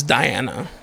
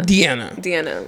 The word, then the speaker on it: Deanna: She's like, no, it's Diana. – Sorry. – Spencer? – It's the same with Diana, because it's spelled D-E. And then Anna, so. – Oh, is it? – Yeah. – Deanna.